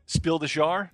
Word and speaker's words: Spiel des Jahres: It's Spiel des Jahres. How do you say it? Spiel de what? – Spiel des Jahres.